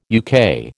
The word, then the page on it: UK: American English